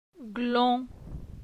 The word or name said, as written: Glon